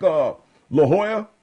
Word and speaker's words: Jolla: J-O-L-L-A. Silent L's. – Well, not really. – Yeah, well, I mean, they're pronounced like a Y.